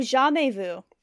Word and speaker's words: jamais vu: Have you ever heard of, and again, I'm sorry if I'm not pronouncing this correctly, but jamais vu? – Oh, jamais vu.